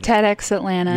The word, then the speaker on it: TEDx Atlanta: TEDx Atlanta.